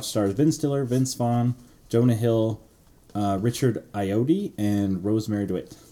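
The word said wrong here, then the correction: Ayoade: Did I say that wrong? Is it Iowati? – Iowati.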